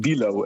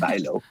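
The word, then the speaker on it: Bijlow: En Bilo, dat is Bijlo. Dus ze kunnen de Nederlandse naam niet goed uitspreken.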